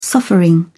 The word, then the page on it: suffering: British English